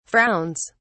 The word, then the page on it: frowns: British English